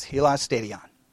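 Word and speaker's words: hilasterion: If you want to write out how it would look, transliteration, it would be spelled H-I-L-A-S. T-E-R-I-O-N. H-I-L-A-S-T-E-R-I-O-N. A-S-T-E-R-I-O-N, Helios Stadion. All right? I know it doesn't look like that, but that's how it's pronounced, Helios Stadion.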